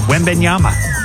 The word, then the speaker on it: Wembanyama: Wembanyama. I said that right, didn't I? Wembanyama.